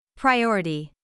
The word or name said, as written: priority